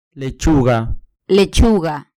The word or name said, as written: lechuga